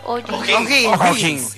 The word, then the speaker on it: O'Higgins: ¿Se pronuncia O'Higgins? – O'Higgins.